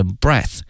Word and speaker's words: breath: 同时啊，呼吸这个词因为呢它的词性是名词，因此它的发音是 breath，而它的动词呢是 breathe， breathe。